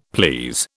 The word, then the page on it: please: British English